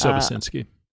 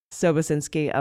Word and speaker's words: Sobocinski: His name is Sobosinski. I hope I'm pronouncing that right. I've heard it pronounced a few different ways. – Yeah, I think it's Sobosinski. – Sobosinski, okay. Well, he testified, I think, before Jim Jordan's Judiciary Committee behind closed doors.